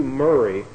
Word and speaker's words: Maury: And it was written by a scientist by the name of Matthew Murray, and it's spelled M-A-U-R-Y, Murray, Matthew Murray.